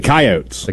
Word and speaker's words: Coyotes: Not the Coyotes, the Coyotes. – The Coyotes. – The Coyotes is how they pronounce it.